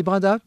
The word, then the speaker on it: Brindas: Voilà, on dit Brindas Brindas, je ne sais pas. – C'est comme Brindas, on prononce pas le X,